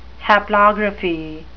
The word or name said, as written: haplography